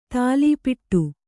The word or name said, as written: ಥಾಲೀಪಿಟ್ಟು